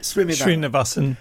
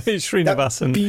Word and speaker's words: Srinivasan: Yeah, I mean, while we're on this subject, because the ones who really stand out for me, I mean, Ashwin's, please pronounce it, Srinivasan, right? – Srinivasan, yeah, you know, Srinivasan.